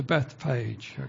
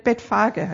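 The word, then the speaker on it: Bethphage: Also das ist ein kleiner Weg, Betfage heißt es.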